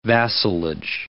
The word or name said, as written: vassalage